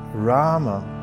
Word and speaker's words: Rama: Through this mantra, pronounced Rama, one can find the wellspring of happiness that is there within one's own self the mantra itself reveals that happiness.